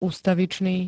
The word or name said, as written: ustavičný